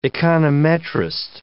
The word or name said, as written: econometrist